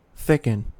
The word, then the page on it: thicken: American English